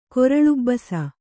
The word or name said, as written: ಕೊರಲುಬ್ಬಸ